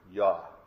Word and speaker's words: Yah: If you have a King James Bible, it's spelled J-A-H, but if the pronunciation of it is just two letters, Yod and He in Hebrew, Yah.